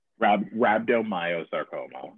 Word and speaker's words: rhabdomyosarcoma: Rhabdomyosarcoma.